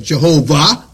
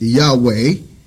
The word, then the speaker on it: Yahweh: And of course we know Yah, we get, well, people said Jehovah, because that's a mispronunciation. They took the vowel pointings for Adonai, 15th century monk did, and made it Jehovah. But closer is Yahweh, and of course we know Yahshua.